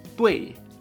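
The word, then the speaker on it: tui (对): Y correcto, en chino se dice tui. Aunque se escriba D-U-I, añadiremos esa E. Tui.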